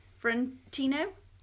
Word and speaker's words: Fiorentino: So, Linda Fiorenti. Thank you, Fiorentino. Is that right? – Yeah.